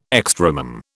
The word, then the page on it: extremum: British English